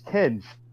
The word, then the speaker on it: Kenj: I mean, uh, y'all got pissed off last time I said it, but y'all been pronouncing my name wrong like the whole time. I don't really care. It was just like funny. – Well, how you say it? What, what, what is it? It's Kenj.